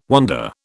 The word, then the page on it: wander: British English